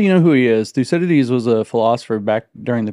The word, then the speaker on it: Thucydides: And, you talk about the, see if I say it right, the Thucydides trap. – Thucydides. – Right? Thucydides. – Thucydides trap. – Yeah, Thucydides trap. It's a Greek guy, yeah.